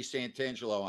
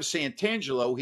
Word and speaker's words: Santangelo: Instead of Santangelo, he was calling him Santangelo without the T.